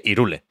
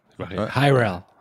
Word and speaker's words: Hyrule: O sea, Zelda dice Irule, como no lo voy a decir yo. Hyrule. Hyrule, Para ellos, vaya.